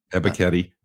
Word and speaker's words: Ebiketie: So the other one that really kind of stood out when I was looking at it here's a name I can't pronounce. It's Arnold Ebiketie. – Is it Ebiketie? – Ebiketie. Yeah.